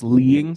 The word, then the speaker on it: Lied: This anime is pronounced Leeing, so don't get it wrong.